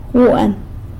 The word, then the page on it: guan: sanlucasquiavini